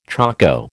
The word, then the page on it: choko: American English